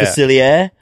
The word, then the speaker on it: Facilia: Facilia.